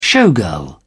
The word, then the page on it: showgirl: British English